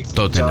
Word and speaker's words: Tottenham: Todos los equipos que terminan con ham, uh-huh, H-A-M, bueno, la H es muda. Ejemplo, Tottenham, Birmingham. Creo que la única excepción es West Ham, pero justamente porque se escribe separado.